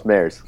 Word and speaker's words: Mares: Justin, did I say your name right? – How do you? Close, Maris.